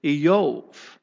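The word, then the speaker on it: Iyov: The Hebrew pronunciation of his name is Eyov. We've always, growing up in church, just called him Job.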